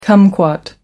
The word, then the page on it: kumquat: American English